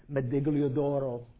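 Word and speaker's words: Medaglia d'Oro: Like very kindly, my hosts, David and Karen, bought some Medaglio d'Oro. Is that a good pronunciation? Good enough. I'm not Italian, good Italian.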